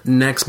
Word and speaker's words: Nex: Picked up a game called Nex Machina. I don't know if you've heard of this game. – I have. – Yeah, so it's Nex, N-E-X, not Next.